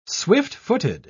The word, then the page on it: swift-footed: British English